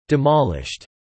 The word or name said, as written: demolished